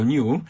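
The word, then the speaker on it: Nu: Dlatego Światowa Organizacja Zdrowia zdecydowała się, żeby nie było tego wariantu. Wariantu Ni nie było, dlatego że po angielsku czyta się jako New, więc byłoby to mylące.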